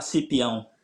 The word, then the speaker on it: Cipiao: Uttalade jag ditt eh, efternamn rätt där? Ja, Romario Pereira Cipiao.